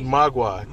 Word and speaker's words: Mogwai: Gremlin Secrets of Mogwai? – Mogwai. Get it right. – Never could pronounce it.